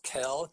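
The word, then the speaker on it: Kehl: Yes, hello, my name is Travis Kell.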